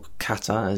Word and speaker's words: Qatar: So I was living in Qatar, mm-hmm. – or Qatar as it should be pronounced, but we put the stress on the wrong part of the word, in the Middle East, which is a tiny little Gulf country next to Saudi Arabia.